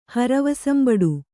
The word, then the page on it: ಹರವಸಂಬಡು: Kannada